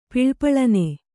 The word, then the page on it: ಪಳಪಳನೆ: paḷa paḷane